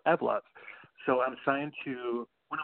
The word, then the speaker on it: Evlove: Yeah, Evlove. Think of evolve and love together. – Okay, so how do you pronounce it again? – Evlove.